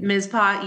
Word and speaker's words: Mizpah: I've been wanting to talk about the Mizpah Hotel. And did I say that correctly? As soon as it came out, I'm like, Mizpah, yes.